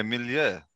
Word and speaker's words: milieu: Thank you, for your outstanding contribution to the cultural milieu. You always use... You've got a vocabulary. – You're saying that wrong. – I used the Google. – Milieu. – French. – Yeah, milieu.